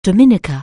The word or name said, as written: Dominica